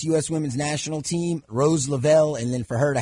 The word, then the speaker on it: Lavelle: How do you pronounce Rose's last name, Cody? – Lavelle.